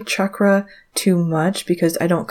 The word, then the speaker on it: chakra: chakras. And the reason I say chakra and not chakra is some people reached out to let me know that the correct pronunciation is chakra,